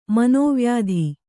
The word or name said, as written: ಮಾನವೀಯತೆ